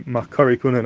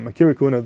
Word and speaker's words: מכירי כהונה: The Yavitz in his Agayis and Baba Basra, Daf Kuf Chaf Gimel and Beis, also says that the proper way to pronounce it would be Makari Kohuna, not Makiri Kohuna, the same Hechach Alpi, the Pasuk in Malachim, which is Ishmes Makari.